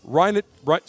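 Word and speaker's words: Reinheitsgebot: In 1516, William IV, the Duke of Bavaria, adopted the Rheingespott. I believe I'm pronouncing that correctly. – Rheinhutzgebot. – Say that again.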